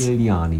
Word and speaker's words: Modigliani: Modigliani, man lässt das G einfach weg.